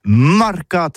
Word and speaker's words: marcats: c'est prononcé sur les marcats, avec un A Marque. En gascogne toulousaine, bah, du côté, par exemple, de l'île des Bâches, c'est l'île Jourdain, pour parler du marché, vous prononcez les marcats.